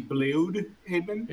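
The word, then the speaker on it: Blüdhaven: Which most people call it Bloodhaven because it sounds cool, but it's actually Bloodhaven.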